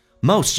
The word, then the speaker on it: most: Time is the most， most 这个发音要非常的饱满，先发 a 再发 u，嘴巴掉到极限往前推再往上，有一个弧度叫 most。 Most T letter T is silent， T 不发音，后面是以辅音开头，所以这个叫略音。